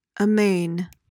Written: amain

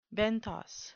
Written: benthos

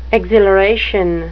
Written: exhilaration

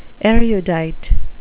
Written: erudite

